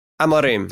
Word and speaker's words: Amorim: Amorim.